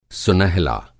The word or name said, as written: सुनहला